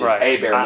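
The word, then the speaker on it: Hébert: So it's H-E-B-E-R-T, which is A Bear Louisiana.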